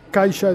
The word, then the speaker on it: Caixa: Caixa es la pronunciación correcta.